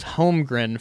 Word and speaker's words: Holmgren: I have been checking the pronunciation guide for the Thunder, and I am going to burn it into my brain that it is Holmgren for it.